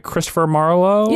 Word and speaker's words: Christopher Marlowe: Christopher Marlowe? – Yeah.